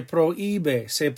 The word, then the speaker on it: prohíbe: Remember the H is silent. Se prohíbe fumar.